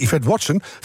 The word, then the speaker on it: Yvette Watson: En ons groene geweten is deze keer Yvette Watson van de V-Factory. – Yes! – Eindelijk goed uitgesproken na 30 jaar.